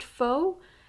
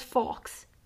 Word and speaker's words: faux: In my opinion, the Bear With Me and Faux Fur combo, I stopped watching him because he's pronounced faux as fox. Fox Fur. No, it's Faux Fur, you idiot, SpenLC.